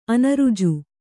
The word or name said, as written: ಅನೃಜು